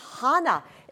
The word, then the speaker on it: Hannah: That name is Hannah. If you notice very carefully, this is an aspiration, and this means that you pronounce an H sound.